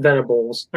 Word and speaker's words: Venables: Brent Venables.